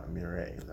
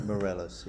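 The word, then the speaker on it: Meirelles: Talked a little bit about Fernando Mireles. I think I always pronounce his last name wrong. – Mireles, yeah. – Mireles, thank you.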